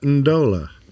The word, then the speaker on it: Ndola: Performing a flight from Goma, DR Congo to, there's no vowel in there between the N and the D. I'm not sure how to pronounce that. – Ndola. – Zambia. – No, Steph got it right. Ndola.